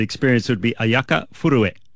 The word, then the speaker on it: Furue: I've got to get the pronunciation right here. Japan's Ayaka Furue is how... – Pretty good. – Is pretty good. – Because it's spelled F-U-R-U-E, and I was watching the coverage going, Furue.